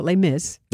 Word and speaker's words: Les Mis: I've had you in before to talk about your experience with Les Miserables, or we just call it Les Mis. – We can call it Les Mis. – Because it rhymes with The Miz? – The Miz, yeah, exactly.